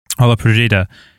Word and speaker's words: Ola Podrida: Yeah, it's interesting to think about, you know, both your compositional work and your work as, is it Ola Podrida? How do you pronounce it? – Yeah, that's it. – Ola Podrida.